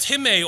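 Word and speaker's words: timē: Well, this word honor is spelt like time, T-I-M-E, but it's pronounced time.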